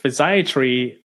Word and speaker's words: physiatry: Yeah, so physiatry, you pronounce it correctly, is the same as physical medicine and rehabilitation.